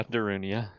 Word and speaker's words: Darunia: Darunia is my number five. He is. Am I pronouncing that right? Because you're the anal one about how names work. – Yeah, Darunia.